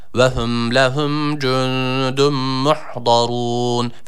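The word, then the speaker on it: Muhdarun (مُحْضَرُونَ): Birincisi ha harfi, sakin olan ha harfine dikkat edelim. Bunu he ile okumayalım. Müh, muhzarun yanlış okuyuş olur.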